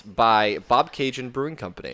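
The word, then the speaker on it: Bobcaygeon: Ryan didn't know how to pronounce the word, and I was like, it's pronounced Bob Cajun, exactly pronounced and spelled, like the Tragically Hip song.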